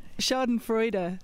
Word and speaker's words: Schadenfreude: So Schadenfreude, so four syllables.